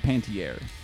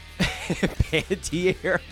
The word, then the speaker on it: Panettiere: I always get her confused with Hayden Panettiere. – Panettiere? – Isn't that how you say it? – Panettiere. – Panettiere. – Not like Panettiere. Like there's a wind flowing betwixt her legs.